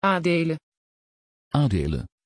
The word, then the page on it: adele: Dutch